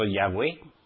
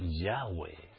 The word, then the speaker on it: Yahweh: En vez de Jehová han puesto Yahweh. Pero con acento en la E, la han puesto. Y no es así, es Yahweh, sin acento en la E.